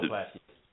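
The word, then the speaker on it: Plaschke: Times by, I think you say his name, Plasky. He's on ESPN all the time. He covers the Dodgers. – Yeah, I know Bill Plasky.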